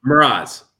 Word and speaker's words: Mroz: Mroz. – Mroz. Okay. Like it's good. Like a mirage.